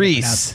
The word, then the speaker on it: Rhys: R-I-C-E? – R-H-Y-S. Oh, now we're gonna try to pronounce this. R-H-Y-S. Is that his name? I don't even... Knows how Reese. – To pronounce Reese. – Probably Reese. – You're right. I'm wrong. – I would say Reese. – How do you say it? How do you pronounce his last name? I-F-A-N-S.